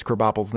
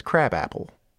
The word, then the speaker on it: Krabappel: Well, because the joke is that Miss Krabappel's name is Crabapple, but it's pronounced Krabappel.